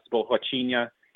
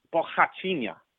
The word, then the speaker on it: Borrachinha: Did I say it? Did I murder it? – Yeah. No, you said it right. Remember, okay, the R's are ancient. Yeah. – Borrachinha. Right. – Yeah. And the NH will give you a nya sound.